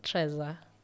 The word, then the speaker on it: Treza: Treza. – Treza. – T-R-E-Z-A.